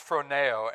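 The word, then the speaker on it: phroneo (φρονέω): The Greek word in this portion of Scripture for set your mind on is a word that's pronounced phroneo, and it translates as think or mind, or be of the same mind.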